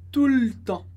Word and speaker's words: tout le temps: A gente diz mais como tout le temps. Tout le temps. Te corta o E e fica tout le temps.